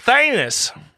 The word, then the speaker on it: Thanos: I'm talking about Thanos. What a beautiful Thanos. – Yeah, well, sorry, sorry, my Midwest. Forgive me. I like to make my A's into E's. – It's pronounced Thanos.